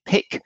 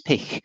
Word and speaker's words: Pich: So David Pich will do, or for any of our Germanic speakers, it's Pich.